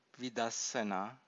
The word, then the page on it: vidassana: Pali